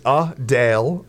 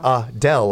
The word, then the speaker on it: Adele: But it's Adele. Adele. Yep. Not Adele, like I just did. – Yeah, exactly. – Like I just mispronounced it.